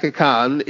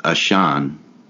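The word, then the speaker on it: Ahcan: Jack Akan is 23. – Apparently, I've learned it's pronounced Ashan.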